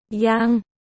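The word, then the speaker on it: Yang (阳): Cả hai đều phát âm là Yang trong tiếng Trung.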